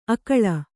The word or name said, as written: ಅಕಳ